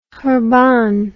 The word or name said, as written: churban